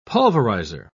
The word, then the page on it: pulverizer: British English